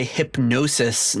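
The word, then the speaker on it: Hipgnosis: So one interesting thing about this album cover is it was created by Hypnosis, not spelled like you think it's spelled, but spelled like hip, like Gnosis, Gnosis, like the mm-hmm. like the mystical Gnosis.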